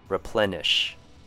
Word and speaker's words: replenish: R has the short U sound, ple has the short E sound, and nish. I know there's an I here, but try saying it with a short U sound instead. Replenish.